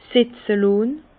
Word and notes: setze lonn: Bas-Rhin; Herrlisheim

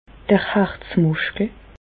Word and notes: d'r Harzmùschkel: Bas-Rhin; Reichshoffen

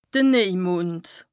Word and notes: de Nejmond: Bas-Rhin; Strasbourg

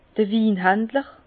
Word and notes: d'r Winhandler: Bas Rhin; Herrlisheim

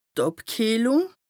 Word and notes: d' Àbkìehlùng: Bas Rhin; Strasbourg

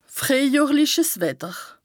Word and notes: frìehjohrliches Wetter: Bas-Rhin; Strasbourg

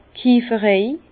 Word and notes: d' Kieferei: Bas-Rhin; Herrlisheim

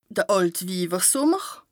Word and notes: de Àltwiiwersùmmer: Bas-Rhin; Strasbourg

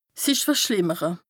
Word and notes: sich verschlìmmere: Bas-Rhin; Strasbourg

